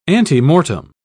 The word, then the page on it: antemortem: British English